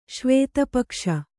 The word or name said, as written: ಶ್ವೇತಪಕ್ಷ